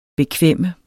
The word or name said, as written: bekvem